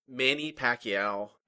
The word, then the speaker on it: Manny Pacquiao: How do you say his name again? – Manny Pacquiao. I've never heard it pronounced. I've only seen it in print. – Manny Pacquiao is his name.